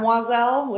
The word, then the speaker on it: Oiselle: Why don't you go ahead and see right here, it's spelled like O-I-S-E-L-L-E. So it's like Madame Wazal without the Madame.